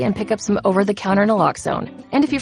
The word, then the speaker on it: naloxone: Naloxone reverses an opioid overdose, even if you mispronounce it. For the record, it's Naloxone. Like, is Naloxone addictive? Lox like Naloxone keeps humans safe, like locks keep bikes safe.